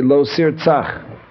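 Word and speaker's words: Lo Sir Tzach (לֹא תִרְצַח): When the Aseres HaDibros are read privately, then you pronounce the first of these last five commandments. You would say, Lo Sir Tzach. The Nikud, the vowel, is a patach.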